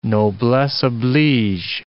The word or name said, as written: noblesse oblige